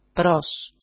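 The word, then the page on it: pros: Greek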